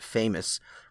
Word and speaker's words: Fame US: The 7-year-old dog named Fame US, pronounced famous, ran an obstacle course in 29.53 seconds to defeat more than 300 competitors.